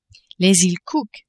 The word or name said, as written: Iles Cook